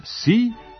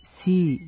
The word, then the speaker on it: Xi: Prema Pinyin transkripciji, njegovo prezime počinje latiničnim slovom X, koje se u tom slogu, XI, na kineskom izgovara kao sasvim blago umekšan glas S, dok je I pod akcentom koji je nalik na naš dugouzlazni.